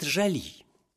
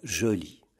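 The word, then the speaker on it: jolie: Ком вузет жали?» Она говорит. «Жали, конечно, надо произносить как «жоли». А что же бы это такое значило?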